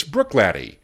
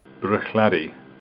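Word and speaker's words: Bruichladdich: Finally, let's look at F-I-D-D-I-C-H. In Speyside, it's Glenfiddich. But if you're on Isla, it's Brookladdie. Give Jim McEwen's gang on the island credit, though.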